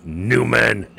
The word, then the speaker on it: Newman: You're not saying it right. Newman.